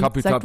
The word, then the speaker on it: Capital Bra: Capital Bra oder, oder wie Leute, die das R rollen können, das aussprechen. Ähm, Kapital sag mal.